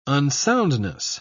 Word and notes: unsoundness: British English

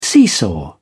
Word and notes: seesaw: British English

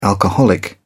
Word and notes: alcoholic: British English

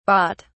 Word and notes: bharat: British English

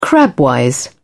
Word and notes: crabwise: British English

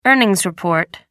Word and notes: earnings report: American English

